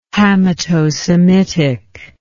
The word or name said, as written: Hamitosemitic